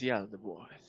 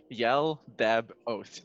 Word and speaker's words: Yaldabaoth: Its name is Yaldabaoth. – Oh god, how do I spell that? – Y-A-L-D-A-B Oath, yep. O-A-T-H. – Thank you. – I just realized it's like Yaldabaoth.